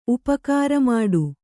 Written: ಉಪಕಾರಮಾಡು

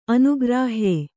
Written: ಅನುಗ್ರಾಹ್ಯ

